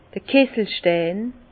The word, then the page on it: d'r Kisselstein: Alsatian; Bas-Rhin; Herrlisheim